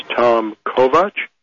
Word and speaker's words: Kovach: And Kovach is spelled K-O-V-A-C-H, just like it sounds.